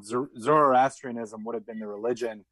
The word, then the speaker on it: Zoroastrianism: Okay, yeah, you're absolutely right, and you pronounced it perfectly right. Zoroastrianism is ostensibly one of the oldest world religions.